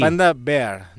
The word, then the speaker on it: Panda Bear: Després he rebut colleses per això i perquè no he pronunciat bé, perquè... és qui? Panda Bear, no Bear.